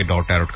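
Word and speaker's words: tarot: অনেকে এটাকে ট্যারো card বলেন, আসলে ইংলিশ উচ্চারণটা বিভিন্নভাবে বিভিন্নভাবে করা যায়, যেহেতু স্পেলিংটা হচ্ছে TARO T, লাস্টের টিটা অনেকে উজ্জ্ব রেখে উচ্চারণ করেন, অনেকে বলেন ট্যারো কার্ড, অনেকে বলেন ট্যারট কার্ড, এটা নিয়ে নাকি রেজা ভাই আমাকে বললেন যে কোন একটা রেডিওতে এটা নিয়ে নাকি কথা বলা হয়েছে।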